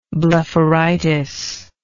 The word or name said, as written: blepharitic